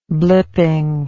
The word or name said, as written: blipping